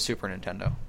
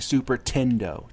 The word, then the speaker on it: Super Nintendo: I also don't like it when people say Super Tendo.